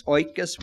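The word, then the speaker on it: oikos (οἶκος): When it says that God dwells in light, that's a Greek word pronounced oikos, which means house or home even.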